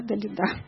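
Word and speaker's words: Далила: и вот как бы как все-таки этому Самсону Далида, правильно, да, Далида, ударение,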